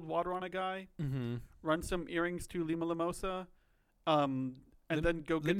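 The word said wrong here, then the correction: Limsa Lominsa: Limsa, how do you pronounce it? – It's Limsa Liminsa, but Limsa Liminsa.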